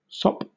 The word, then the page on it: sop: British English